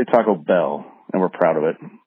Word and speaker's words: Taco Bell: I'm going to look past your terrible slander of Chicago-style pizza, but I got a question about your pronunciation of, as you call it, Taco Bell, with the emphasis on taco, which seems wrong to me. Should be on the bell. Should be Taco Bell, like Taco Jones and, you know, Taco Leroy's or whoever.